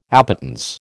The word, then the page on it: appetence: American English